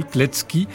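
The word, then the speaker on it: Kletzki: Voici Paul Kletsky, né Pavel Kletsky, ça s'écrit C-K-I, lui remplacera par T-Z-K-I, histoire qu'on le prononce bien.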